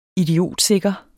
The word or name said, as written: idiotsikre